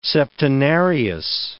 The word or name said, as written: septenarius